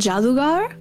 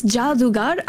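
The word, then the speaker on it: Jaadugar: Like, so it's I think it's Jadugar. I'm not sure because it's J A A D U G A R.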